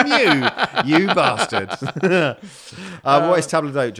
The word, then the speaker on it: table d'hôte: Right, so we're pronouncing this table d'hôte. Table d'hôte. – You got it.